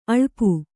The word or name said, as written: ಅಳುಪ